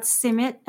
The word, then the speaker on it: simit: Can we talk a little bit about simit? Am I saying it right? – Yes, you are.